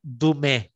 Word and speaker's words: Dumais: Eu gosto muito de um autor que escreveu um estudo muito profundo sobre as bem-aventuranças que se chama Marcel Dumé. Se escreve Marcel Dumais.